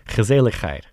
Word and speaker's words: gezelligheid: Gezelligheid the coziness of a magnificent city.